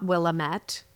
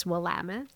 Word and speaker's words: Willamette: In case people are wondering, it's not Willamette for listeners, even though it looks like it. Someone from the region once told me this years ago, and it's always stuck with me. It rhymes with damn it. So Willamette, damn it. So I always think of that when I look at it because I want to say Willamette, but it's Willamette.